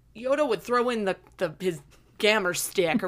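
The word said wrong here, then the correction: gimer stick: or what's it called? – The gimmer stick. – Did I pronounce it? Gimmer stick.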